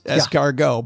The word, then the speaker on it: escargot: There's a T at the end of that word. It's right there. – It is. – Why would we put it there if you're not supposed to pronounce it? – Very good point. However, in this case, it is escargot.